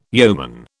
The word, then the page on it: yeoman: British English